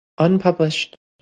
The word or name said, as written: unpublished